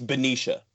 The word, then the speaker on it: Benicia: You're in, is it now, correct me, is it Benicia or Benicia, California? Is that how it's pronounced? What is the correct pronunciation of that? – It's Benicia.